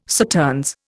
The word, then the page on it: Sauternes: Indian